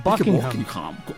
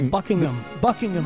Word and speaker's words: Buckingham: Es Buckingham. – Eso sí, y de hecho, inglés perfecto, el H medio que se es medio silente. Buckingham.